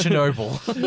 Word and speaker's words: Chernobyl: You're making me say it wrong, like Chernobyl. – It's Chernobyl.